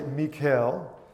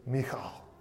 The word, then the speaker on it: Michal: Saul's daughter, Michal, or let's talk about pronunciation here, because if you hear English people, especially American, reading this and pronouncing this, we usually pronounce it Michal, even though it's not spelled like Michael. Okay, some might say Mikhail, but actually probably, did some little bit of research on this today, and I'm going to say it to the best I can, but it's Michal, more like that, kind of with that back of your throat kind of sound as you pronounce it.